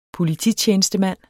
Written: polititjenestemand

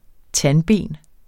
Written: tandben

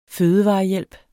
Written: fødevarehjælp

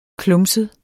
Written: klumset

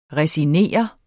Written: resignere

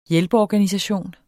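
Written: hjælpeorganisation

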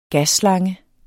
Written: GASSLANGE

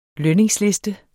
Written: lønningsliste

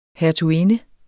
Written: hertuginde

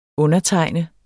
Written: undertegne

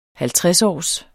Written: halvtredsårs

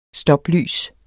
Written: stoplys

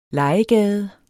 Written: LEGEGADES

